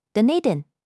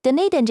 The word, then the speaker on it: Dunedin: 这里的 Wakari Hospital 在新西兰南岛 Dunedin Dunedin 这个地名听新闻时很常见，发音可以抓住前面 do，后面 nadin，不需要每个音都听得很重。